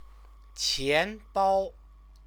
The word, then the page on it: qianbao: Chinese